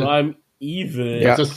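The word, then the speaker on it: Primeval: Ja, bei mir ist das American Prime Well. Ich glaube, ich habe es richtig ausgesprochen. Das ist eine Prime Evil. – Heißt das Prime Evil? – Ja, okay. Yes.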